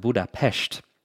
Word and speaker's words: Budapest: Budapest, but it wasn't until 1873 that the two were actually merged and united into the big city that we know today. The Hungarians pronounce it Budapest.